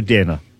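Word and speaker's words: Deyna: Now, it was Dana, Kazimierz Dana. Is that correct? Or I'm saying it wrong? – Yes, sure.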